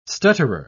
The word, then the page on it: stutterer: British English